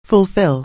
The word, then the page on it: fulfil: anglais